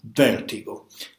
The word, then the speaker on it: Vertigo: Il titolo originale era ancora più significativo perché era Vertigo, con questo curioso accento sdrucciolo, ovviamente deriva dal latino vertigo, cioè vertigine, che è il vero tema poi del film.